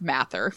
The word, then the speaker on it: Mather: And I always struggle with his name because really close to us here in Sacramento, there's a county or a city called Mather, and it's spelled the exact same way. So, but his name is pronounced Mather.